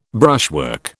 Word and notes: brushwork: British English

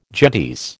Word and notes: gentes: American English

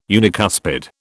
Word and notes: unicuspid: British English

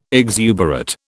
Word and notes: exuberate: British English

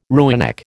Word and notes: rooinek: American English

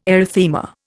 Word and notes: erythema: Indian

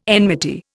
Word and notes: enmity: Indian

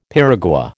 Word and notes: piragua: American English

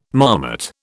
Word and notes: marmot: British English

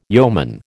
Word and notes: yeoman: American English

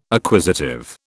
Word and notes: acquisitive: British English

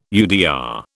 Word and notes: UDR: British English

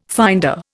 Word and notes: finder: Indian

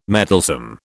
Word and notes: meddlesome: British English

